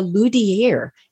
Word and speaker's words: luthier: So today we're going to be talking to a luthier.